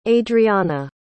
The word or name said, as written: adreanna